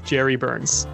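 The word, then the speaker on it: Jere Burns: It's spelled J-E-R-E, which left us a little confused. I think I might actually cut it out in the episode of us trying to figure out how it's pronounced. But yeah, it's Jerry Burns.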